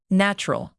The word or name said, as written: natural